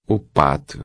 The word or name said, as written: pato